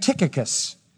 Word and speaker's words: Tychicus: The first one is a man named Tychicus. C-H is pronounced like a K.